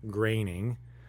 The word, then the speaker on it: Groening: The final person on my list, once I thought of this, was actually, it's a no-brainer now, is Matt Groening.